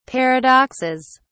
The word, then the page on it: paradoxes: British English